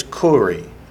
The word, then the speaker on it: Kyrie: This Greek word is kuri. Spell it K-U-R-I-E. If you were more acquainted with Greek, I'd say K-Y-R-I-E, but that would just confuse you. – Kori is what word? – Lord.